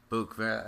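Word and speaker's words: Buhk: Okay, Book.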